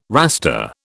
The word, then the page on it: Rasta: British English